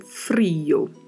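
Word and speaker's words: frío: Tem um acento agudo na letra I e a letra O que é pronunciada mais fechada. Né? Então, frio. A pronúncia é bastante diferente.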